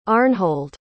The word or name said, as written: Arnhold